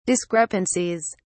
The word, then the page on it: discrepancies: British English